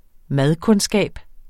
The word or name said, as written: madkundskab